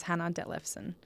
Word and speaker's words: Hanna Dethlefsen: And then me, my name's Hanna Dethlefsen.